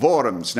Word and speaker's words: Worms: And so he called Luther, and they decided that they would have this diet in Worms. Now, in German, the W is like a V, but Americans don't know that, so they pronounce it the Diet of Worms.